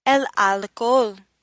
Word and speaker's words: alcohol: El alcohol, the H is silent.